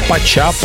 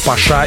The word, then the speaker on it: Pacha: Кстати, многие спорят, как правильно произносить название легендарного заведения. Пача, Паша или Паша. Можно подытожить. Верно все. Зависит от того, на каком языке вы это произносите.